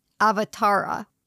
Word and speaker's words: avatara: The word avatar comes from the Sanskrit word avatara, A-V-A-T-A-R-A, which is actually pronounced, let me see if I can do this right. I listen to it over and over and over again. Avatar. Avatar.